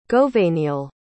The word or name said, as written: gouveniail